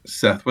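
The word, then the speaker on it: Ceth: Yeah, it is, it's C-E-T-H, Seth with a C.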